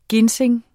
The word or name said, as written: ginseng